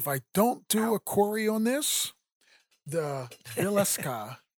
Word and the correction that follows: Villisca: In Villisca, Iowa. Guys ever heard of that place? Probably not. – Not the way you pronounced it.